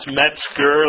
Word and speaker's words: Metzger: It's Metzger, like the New York Mets, and what a tiger says, Ger, okay?